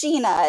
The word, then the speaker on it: Gina: It's Gina. It's like G-I-N-A, but I mean, it looks like Jenna, and people call me that all the time.